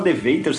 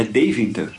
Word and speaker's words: Deventer: Mas a gente descobriu, como The Venter é o nome da nossa cidade na Holanda, a gente descobriu que a pronúncia em holandês é Deventer.